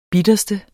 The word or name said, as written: bitterste